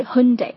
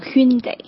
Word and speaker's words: Hyundai: 正确发音应该是 Hyundai， OK， Hyundai，有些人会发成 Hyundai，这个是错误的，应该是 Hyundai， OK。